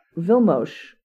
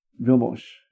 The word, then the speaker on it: Vilmos: Vilmos Zsigmond? – Vilmos. – Vilmos. – Will be how his first name is pronounced.